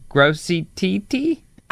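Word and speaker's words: Grosse Tete: Is that how you pronounce this? Gross? – Well, spell it for me. – T-E-T-E. Gross teat. – It's all one word? G-R-O-S-S? – Oh, no. G-R-O-S-S-E. Grossy-T-T? Grossy-T-T. – Grossy-T-T. – Grossy-T-T. – Them there. Grossy-T-T.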